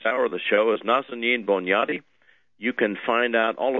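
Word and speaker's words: Nazanin Boniadi: Nazanin's first name is spelled N-A-Z-A-N-I-N. That's N-A-Z-A-N-I-N. Last name Bonjati spelled B-O-N-I-A-D-I. That's B-O-N-I-A-D-I. IADI, pronounced Nazanin Bonjati, born in Tehran, Iran.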